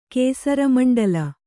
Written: ಕೇಸರಮಂಡಲ

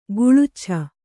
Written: ಗುಳುಚ್ಛ

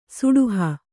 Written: ಸೆಡಹು

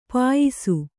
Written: ಪಯಸ್ಸು

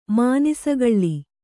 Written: ಮಾನಿಸಗಳ್ಳಿ

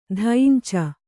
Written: ದೈಂಚ

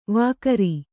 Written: ವಕ್ರೆ

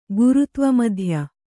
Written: ಗುರುತ್ವಮಧ್ಯ

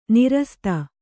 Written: ನೀರಸತೆ

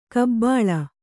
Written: ಗಬ್ಬಲು

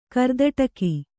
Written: ಕರ್ದಟಕಿ

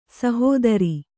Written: ಸಹೋದರ

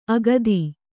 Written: ಅಗದ

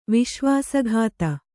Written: ವಿಶ್ವಾಸಘಾತ

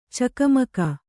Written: ಚಕಮಕ

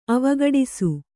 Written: ಅವಘಟಿಸು

